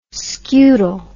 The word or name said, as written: scutal